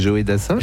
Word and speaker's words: Joe Dassin: Je ne connaissais pas cette manière de prononcer Joe Dassin. – C'est Joe Dassin.